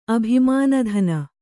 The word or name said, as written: ಅಭಿಮಾನಧನ